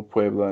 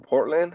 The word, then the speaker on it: Puebla: So if we're looking at Friday night, it's Monarcas, Morelia, Cruz Azul, Puebla, and Tijuana. – I believe I heard Portland. It's Puebla.